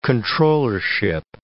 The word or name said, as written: comptrollership